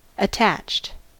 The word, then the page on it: attached: American English